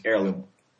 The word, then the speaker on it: Heirloom: You don't pronounce the H. It's Heirloom.